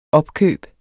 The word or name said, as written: opkøb